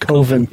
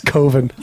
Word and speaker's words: coven: That's pronounced Coven. – Is it Coven? – It's no. Coven. Because it's spelled like oven. It's Coven.